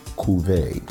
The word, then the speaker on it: cuvée: And it's a Blanc 32 cuvée. So those who doesn't know the cuvée meaning, it's spelled C-U-V-E-E.